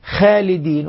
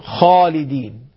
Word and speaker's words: خالدين: فلذلك بالإضافة لذلك الصفات تعطي الحرف جمالا في الأداء، يعني ننطق الحرف كما نطقته العرب، لأنه في فرق بين أقول خالدين وأنا نطقت خاء هنا من مخرجها خاء، لكني لم أعطيها صفة الاستعلاء، والصواب أن أقول خالدين، خاء غير خاء.